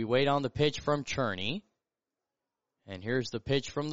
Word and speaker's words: Cerny: Andrews Jr. So Cherney is C-E-R-N-Y, but it's pronounced Cherney, as that's a called strike.